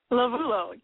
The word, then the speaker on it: Lovullo: Lovullo, yes.